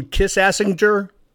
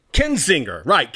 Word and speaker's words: Kinzinger: Adam Kissassinger? Am I saying that right? Kissassinger? Adam Kissassinger? You know the guy I'm talking about. Kinzinger, right. Thanks for correcting me.